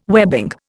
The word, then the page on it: webbing: Indian